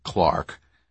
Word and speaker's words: clerk: Especially if he's a mail clerk. – He pronounced it Clark.